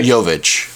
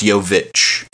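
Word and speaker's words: Jović: Jovic. – Okay, I'm not sure. – It's like Jokic, but it's Jovic.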